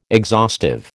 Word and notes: exhaustive: American English

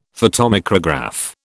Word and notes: photomicrograph: British English

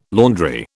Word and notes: laundry: British English

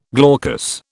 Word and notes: glaucous: British English

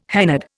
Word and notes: hennaed: Indian

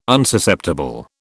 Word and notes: Unsusceptible: British English

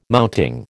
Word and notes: mounting: American English

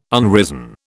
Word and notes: unrisen: British English

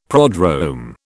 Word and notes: prodrome: British English

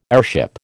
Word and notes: airship: American English

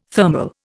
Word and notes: thermal: Indian

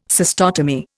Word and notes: cystotomy: Indian

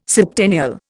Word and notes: septennial: Indian

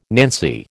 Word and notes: nancy: American English

